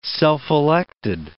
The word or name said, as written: self-elected